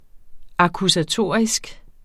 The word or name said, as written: akkusatorisk